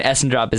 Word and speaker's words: Essendrop: E-N-O-C-H. And Essendrop is exactly how it sounds. E-S-S-E-N-D-R-O-P.